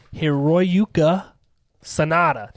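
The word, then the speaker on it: Hiroyuki Sanada: Played by Hiroyuka Sanada.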